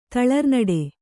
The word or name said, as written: ತಳರ್ನುಡಿ